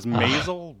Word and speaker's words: Maisel: He's in 32 episodes of The Marvelous Mrs. Moistel. I don't know how it's pronounced. – I thought it was Maisel, but what do I know? – Sure, that makes more sense the way it's spelled.